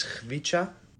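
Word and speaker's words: Khvicha: The key is when there's a K and an H, you pronounce that as kh. I know that's very difficult. But then when there's T and an S next to each other, you pronounce that as ts. So it comes out to his first name is Hvica.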